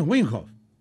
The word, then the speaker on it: Wim Hof: Para mí es Wim Hof. Wim Hof Method. A ver. – Wim Hof Method. – Está bien. – Wim Hof Method. – Wim Hof Method.